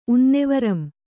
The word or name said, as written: ಉನ್ನೆವರಂ